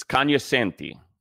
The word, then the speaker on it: Cognoscenti: It's Cognoscenti. The G is silent.